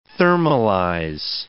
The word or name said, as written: thermalize